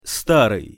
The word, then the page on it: staryj: Russian